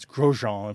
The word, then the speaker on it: Grosjean: I don't know if he pronounces his last name in French, but it's Grosjean in French.